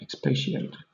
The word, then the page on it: expatiate: British English